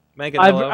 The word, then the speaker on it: Manganiello: I don't know if his name is Joe Mangello. – I can never say it right, so I just go with Mangello. – Manganiello.